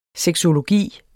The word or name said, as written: sexologi